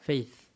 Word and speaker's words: Faith: Ça se prononce comme ça Faith. F-A-I-T-H. F-A-I-T-H, Fave.